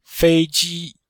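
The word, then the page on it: feiji: fei1ji1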